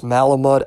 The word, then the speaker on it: Malamud: Um, that one's by Bernard Matt I think it's pronounced Malamud, M A L A M U D.